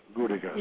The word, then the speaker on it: Gudegast: And your German name was Hans Gudegast. Gudegast. No. You pronounced it very correctly.